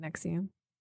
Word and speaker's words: NXIVM: Is it Nexium? Nexium? – Yes. – Wrestling. NXIVM. – That's how I pronounce it. – I think it's like Nexium.